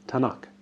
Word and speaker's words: Tanakh: Now TNK is hard to pronounce, so if you put some vowels in there, you get Tanakh.